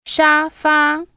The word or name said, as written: ShaFa